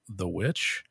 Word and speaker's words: VVitch: I think it's just called The Witch. But it's spelled V-V-I-T-C-H. It's like the old English spelling.